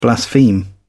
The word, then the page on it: blaspheme: British English